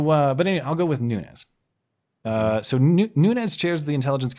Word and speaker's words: Nunes: which I have heard his name pronounced everything from Nunez to Nunes to Nunes. So I'm going to go with Nunes because he doesn't have the little tilde above his N like the Spanish pronunciation.